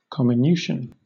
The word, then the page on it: comminution: British English